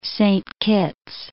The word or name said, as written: Saint Kitts